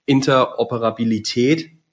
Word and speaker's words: Interoperabilität: Und du hast eben gerade schon richtig gesagt, Interoperabilität, schwieriges Wort, gut ausgesprochen.